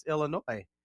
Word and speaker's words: Illinois: And I said, it's Illinois. – Is there, okay, it's Illinois. – Yeah. – The S is silent.